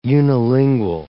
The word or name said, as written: unilingual